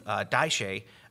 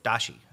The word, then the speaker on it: dashi: daishi, its meaty flavor. Dashi.